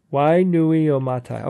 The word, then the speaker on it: Wainuiomata: Wainuiomata. Okay, I pronounced it right.